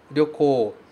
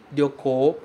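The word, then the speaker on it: Ryoko (旅行): Cuidado com a pronúncia de Ryoko, porque esse começo pode ser um pouquinho difícil de falar, certo? Ryo, Ryo, Ryo. É uma sílaba só. A gente não pode falar Ryo, Ryo, como se fossem duas coisas. Ryo, Ryo. E sim, Ryo, Ryo. De uma vez só, ok?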